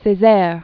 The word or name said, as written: Cesaire, Aime Fernand